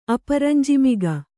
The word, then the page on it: ಅಪರಂಜಿಮಿಗ: Kannada